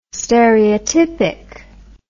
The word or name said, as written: stereotypic